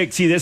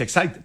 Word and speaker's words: Exide: Como quiera, Hormuz u Hormuz, dentro se escribe Exide, se pronuncia Exide, dentro decía la...